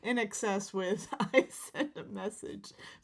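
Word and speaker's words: INXS: Or Inkses. – Inkses. No, that would be Inses. – Oh. – Because an X would be pronounced as a C in the middle of a word.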